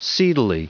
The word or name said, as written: seedily